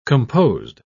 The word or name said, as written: composed